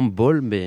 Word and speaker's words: Bol: Et comme ça, on a retrouvé la référence, c'est Bol. Je ne sais pas si je le prononce bien. – Oui, exactement, Bol, ce groupe de musique instrumentale de Toulouse, si je ne me trompe pas, totalement dans les lignées du groupe de Foulou.